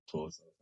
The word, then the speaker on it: Xhosa: It's Xhosa.